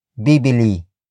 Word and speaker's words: bibili: That is, bibili. Bibili. Bibili. Bibili is spelled as B-I-B-I-L-I.